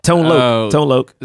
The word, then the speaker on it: Tone Loc: Tone Loc.